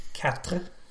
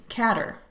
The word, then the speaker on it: quatre: Um, and of course, the English um, made it their own, and instead of pronouncing it, Quatre. it became catter, um, C A T E R, meaning um, to cut or move or go diagonally.